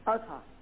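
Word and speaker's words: Ahaz: How you pronounce it? Azah? – Yeah, Azah.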